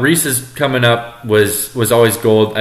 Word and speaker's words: Reese's: I know I say Reese's and it's wrong. It's Reese's.